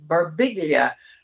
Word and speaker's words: Birbiglia: And it's his, but it's Birbiglia. And it's like Modigliani. You don't say Modigliani, but he pronounces his name Birbiglia.